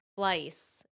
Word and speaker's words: Fleiss: Heidi Fleiss.